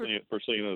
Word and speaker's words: Percina: Percina is correct.